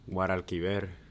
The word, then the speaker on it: Guadalquivir: Guadalquivir.